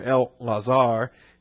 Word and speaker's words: Eleazar: Eleazar, you see in the Hebrew there, El Lazar, it would be how it'd be pronounced,